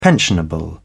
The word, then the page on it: pensionable: British English